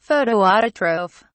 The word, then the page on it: photoautotroph: British English